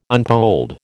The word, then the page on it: unpolled: American English